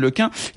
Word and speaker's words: LeCun: Vous voyez, et puis alors il raconte Yann LeCun, il y a un prix Nobel de physique américain qui s'est offusqué que je prononce mon nom en un, car il pensait que c'était impossible en breton.